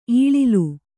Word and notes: ಇಲಾಲಿ: ilāli

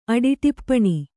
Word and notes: ಅಡಿಟಿಪ್ಪಣಿ: aḍiṭippaṇi

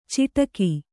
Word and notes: ಚಿಟಕು: Kannada; ciṭaku

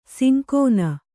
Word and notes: ಸಂಗಿನಿ: sangini

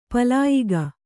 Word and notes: ಪಲಾಯಿಗ: palāyiga

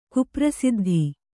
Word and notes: ಕುಪ್ರಸಿದ್ಧಿ: Kannada

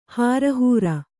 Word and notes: ಹಾರಹೂರ: hārahūra

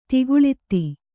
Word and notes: ದಾಖಲಾತು: kannada

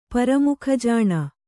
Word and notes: ಪರಮುಖಜಾಣ: kannada

